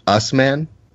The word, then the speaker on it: Usman: I believe it's pronounced Usman. Just Usman. Usman.